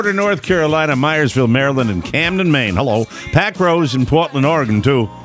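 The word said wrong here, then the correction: Parkrose: They don't say it like that. It's Park Rose.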